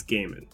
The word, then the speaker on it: Gaiman: He says it's Gaiman. Okay. He says Gaiman. So I've been okay, saying it wrong this whole time. – That's okay. You were breaking down the entomology in a different way. That's yeah. – I stand corrected. I sit corrected because I'm not standing. – Yeah. – Gaiman, Neil Gaiman. I will do my best to never say Gaiman ever again unless pronouncing somebody else's name.